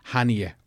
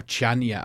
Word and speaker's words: Chania: The flight, it's Ryanair fly into Hania, or Chania as it's written, but it's pronounced Hania.